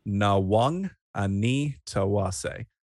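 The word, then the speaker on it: Nawaqanitawase: You can't go past the efforts of Mark Nwanganitawase. And for those of you who don't know how to say the name, Nwanganitawase. It's not that hard. Learn it.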